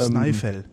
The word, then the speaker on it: Snæfell: Ja, ja, dieser, dieser AE-Buchstabe, den es im Isländischen, ich glaube auch im Schwedischen, Norwegischen gibt, wird hier halt wie Ei ausgesprochen. Ei, also Snæfell.